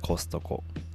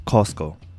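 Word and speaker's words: Costco: 日本語ではコストコ、英語ではコスコ。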